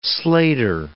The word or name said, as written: slater